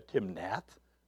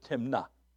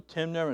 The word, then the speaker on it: Timnath: By the way, I had to go on the computer and ask how that is pronounced, because it's got the E-T-H there, Timnath. No, they pronounce it Timnah, and the E-T-H is silent. And Samson went down to Timnah and saw a woman in Timnah and the daughters of the daughters of the Philistines.